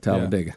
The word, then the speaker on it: Talladega: We always said it. That's how the locals say it, Talladega.